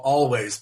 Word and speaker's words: Alvvays: And I really like this band called Always, but it's spelt with two Vs, A-L-V-V-A-Y-S, kind of a small indie record, but it's just really catchy.